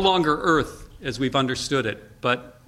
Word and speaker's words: Eaarth: A couple of years ago, McKibben wrote another book called Earth, spelled E-A-A-R-T-H, Earth. I don't know. He suggests that to pronounce it, you have to summon up your inner Schwarzenegger, Earth, or something like that.